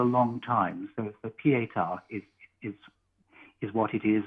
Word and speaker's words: Pietà: It's Pietà, with an accent on the final A.